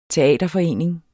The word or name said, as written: teaterforening